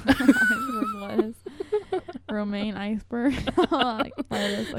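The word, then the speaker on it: Romain: Scarlett Johansson is engaged to a journalist boyfriend, Romaine, like the lettuce.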